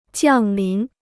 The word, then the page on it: 降临: jiàng lín